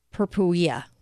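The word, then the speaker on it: Sarracenia purpurea: This is why pay attention to Saracena purpurea. I've been saying it wrong, I guess. Purpurea.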